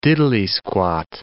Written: diddly-squat

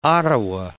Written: Ottawa

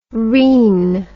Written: reen

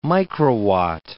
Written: microwatt

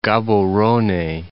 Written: Gaborone

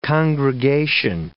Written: congregation

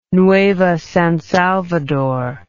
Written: nueva san salvador

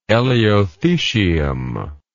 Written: elaeothesium

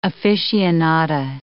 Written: aficionada

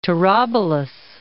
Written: Tarabulus

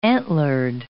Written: antlered

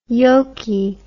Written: yolky

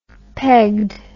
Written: pegged

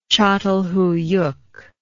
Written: catalhuyuk

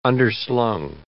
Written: underslung

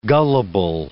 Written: gullable

